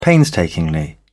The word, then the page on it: painstakingly: British English